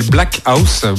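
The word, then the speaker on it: Blaque House: le label Black House, Black qui s'écrit B-L-A-Q-U-E.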